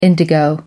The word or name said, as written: indigo